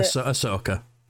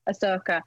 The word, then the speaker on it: Ahsoka: I don't know if I'm pronouncing her name right, because I said Ahsoka, but I don't think that's right. – Yeah, Ahsoka. – Ahsoka.